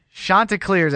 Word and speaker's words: Chanticleers: But the Chanticleers, I believe, is how you pronounce that name. Ryan Perrault, the media relations guy, sent out a pronunciation guide.